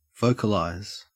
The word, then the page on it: vocalize: Australian English